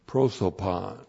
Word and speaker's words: prosopon: And then that's N is N plus the dative of prosopon. P-R-O-S, long O, P-O-N, prosopon.